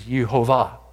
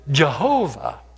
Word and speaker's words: Jehovah: The word Lord in the original language is Yehovah, and we pronounce it Jehovah.